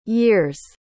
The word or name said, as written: year's